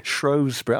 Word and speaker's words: Shrewsbury: Are you pronouncing that correctly? – Shrewsbury. I'm pronouncing it as Kelly told me to.